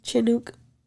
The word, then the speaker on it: Chinook: I think I said that right. Chinook. – Sounds right.